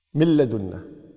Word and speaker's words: من لدنا: من لدنا، من لدنا، من رحمته، من رحمته، مر مر على طول، من رحمته، من لدنا ما في نون، مل ميم لام على طول مباشرة مكتوب النون لكن ما تلفظ.